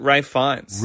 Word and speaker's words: Ralph Fiennes: Ralph Fiennes. – No, it's Rafe. You pronounce it Rafe Fiennes.